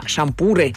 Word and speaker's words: шампуры: Димон нам пишет, меня раздражает, и я всегда поправляю людей, когда они говорят «насаживаем на шампура». Правильно говорить «на шампуры». Шампуры, пардон. Шампуры на их ударение.